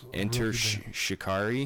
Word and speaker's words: Enter Shikari: That's Enter Shikari. – Shikari.